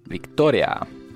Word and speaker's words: Victoria: På spanska skulle det uttalas Victoria. Det är de där orna som blir lite mer av ett å då, då.